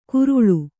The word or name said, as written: ಕುರುಳು